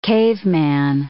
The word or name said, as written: caveman